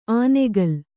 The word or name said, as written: ಆನೆಗಲ್